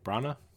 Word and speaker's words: Branagh: Thor is the fourth entry in the Marvel Cinematic Universe, directed by Kenneth Branagh. Is that how you say it? – Branagh? Yeah.